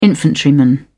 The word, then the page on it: infantryman: British English